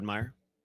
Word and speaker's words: Meijer: M-E-Y-E-R, Fred Meyer. – Boy, that's weird. I mean, they call it Meyer. So well, they spell it M-E-I-J-E-R.